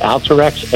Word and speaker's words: Alteryx: I believe you pronounce it Alteryx. A-Y-X.